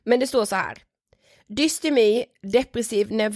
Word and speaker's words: dystymi: Ja vi visste typ inte hur vi skulle uttala det. Jo men det är dystemi. Ja dystemi.